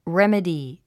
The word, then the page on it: remedy: rémədi; レメディ